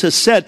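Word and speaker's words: hesed (חסד): And the word grace and mercy in the Hebrew language is the word hesed. It looks like chesed, but the C is silent. It's hesed, and it literally means the loving kindness or the pursuing love of God.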